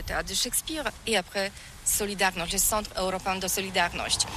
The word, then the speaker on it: Solidarność: Alors solidarité, en français on dit Solidarnosc, mais ici à Gdansk on ne prononce pas Solidarnosc. – Solidarność. Par contre, Solidarność. – C'est assez difficile quand même. – Ça va encore. Solidarność.